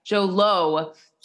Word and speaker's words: Jho Low: No, but when, you know, I tend to think that Hollywood, since they were involved, you know, Jho Kim, and did I pronounce his name right? Jho Low. Yes. Jho Low.